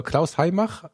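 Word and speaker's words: Klaus Heymach: Äh, Klaus Heimach, äh, zusammengeschrieben. Klaus mit K, Hai schreibt man H-E-Y und dann Mach wie, Mach 1, 2, 3.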